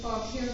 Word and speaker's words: Fauquier: That said, when they seek to expand their service, the DRE is not aggressively expanding, but they are talking now with Fauquier County.